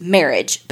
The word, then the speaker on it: Merhige: Well, I Googled pronunciation, and there was a, like, what are those things called? Judge? And it had, like, pronounced marriage beside it.